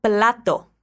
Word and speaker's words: plato: Isso aí, a gente diz plato com a letra L.